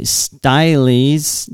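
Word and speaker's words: Sts'ailes: And exhibits the project brings, plans for the expansion include the museum's commitment to continue to honor the Stylies, the area's First Nations people. That's a word I didn't pronounce properly. It's like style.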